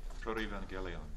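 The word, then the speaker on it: protoevangelium: This is what is, I'm not going to probably say this right, but this is what is called the proto-evangelium. Is that the way they say it? – Proto-evangelion. – Okay. – Like angelos, so it's a hard G rather than a soft G.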